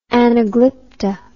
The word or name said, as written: anaglypta